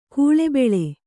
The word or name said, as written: ಕುಳಿಬೀಳು